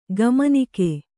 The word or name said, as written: ಕಮ್ಮನಾಗು